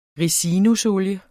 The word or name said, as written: ricinusolie